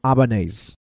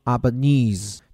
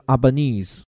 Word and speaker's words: Albanese: 像有的澳洲议员念成 Albanese，还有的念作 Albanese，而阿尔巴尼斯本人呢，自称是叫 Albanese。你看差别主要就在最后这部分。那不管怎么说了，我们这里呢就尊重其本人，念成这个 Albanese，听着有点意大利语的风情哈。